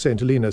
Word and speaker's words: St. Helena: On St. Helena. – Indeed. It's actually pronounced St. Helena. St. Helena is the place in California where Jack London was.